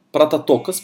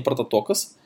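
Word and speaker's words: «прототокос» (πρωτότοκος): В 15 стихе используется слово, которое читается как «протококос», «прототокос», прошу прощения, «прототокос».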